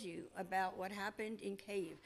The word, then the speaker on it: Kyiv: And please, if you heard Zelensky, he pronounces Kyiv, not Kyiv. – Yeah. – They right. all say Kyiv. Even the news journalists say Kyiv. It's not. Stop it. That's the Russians.